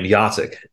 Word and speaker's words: Jacek: Yacek. And how do you spell it? It's spelled J-A-C-E-K. It's not phonetically English.